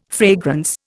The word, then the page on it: fragrance: Indian